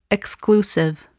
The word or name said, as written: exclusive